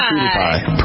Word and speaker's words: PewDiePie: How do you pronounce it? – PewDiePie. – PewDiePie, but it's spelled like PewDiePie. – It's spelled P-E-W. – P-E-W-D-I-E. P-I-E. – Like the Pew Institute. PewDiePie.